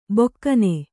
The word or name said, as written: ಬೊಕ್ಕನೆ